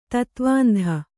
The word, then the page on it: ತತ್ವಾಂಧ: tatvāndha